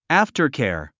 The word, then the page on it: aftercare: ǽftərkὲər; アフターケア